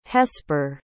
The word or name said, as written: hesper